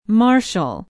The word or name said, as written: marshall